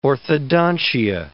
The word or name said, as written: orthodontia